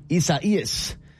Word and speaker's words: Isaias: Isaias. First of all, that is how you say it.